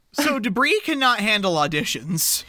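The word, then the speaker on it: De Bris: Debris. – As in trash. – Yeah. – Oh, that's great. – Because it's spelled D-E, capital B-R-I-S.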